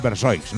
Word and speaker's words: Versoix: Y yo quería preguntarte también, esta ya pregunta mía, que tengo yo mucha curiosidad, porque bueno, yo estoy pronunciando bien el nombre de la banda, que es Versoix, ¿no? – Sí.